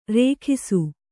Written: ರೇಗಿಸು

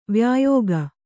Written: ವ್ಯಾಯೋಗ

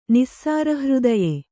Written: ನಿಸ್ಸಾರಹೃದಯೆ

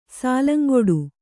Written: ಸಾಲಂಗೊಡು

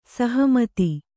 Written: ಸಹಮತ

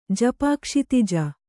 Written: ಜಪಾಕ್ಷಿತಿಜ

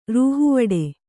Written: ರೂಹುವಡೆ

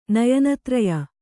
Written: ನಯನತ್ರಯ

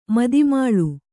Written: ಮದಿಮಾಳು

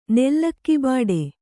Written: ನೆಲ್ಲಕ್ಕಿಬಾಡೆ

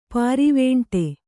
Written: ಪಾರಿವೇಂಟೆ

